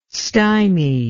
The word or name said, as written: stymie